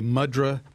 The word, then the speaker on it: mudra: There will be a mudra meditation series. And I looked it up. That's the correct pronunciation. The accent is on the M.